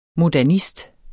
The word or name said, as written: modernist